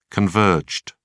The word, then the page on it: converged: British English